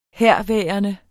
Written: herværende

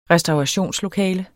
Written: restaurationslokale

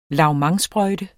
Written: lavementsprøjte

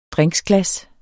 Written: drinksglas

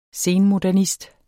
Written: senmodernist

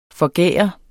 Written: forgære

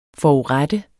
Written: FORURETTET

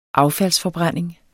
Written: affaldsforbrænding

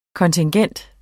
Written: kontingent

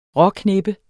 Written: råkneppe

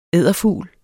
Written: EDDERFUGLE

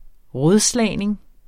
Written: rådslagning

